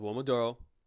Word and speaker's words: Uomodoro: It's called Uomodoro. It's spelled U-O-M-O-D-O-R.